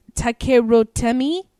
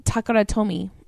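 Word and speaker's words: Takara Tomy: I do sometimes pronounce the merge company is Takerotemi. Okay, but it's Takaratomi.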